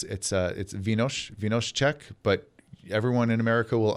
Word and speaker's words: Vino z Czech: The Czechs would pronounce that vee-nos Czech.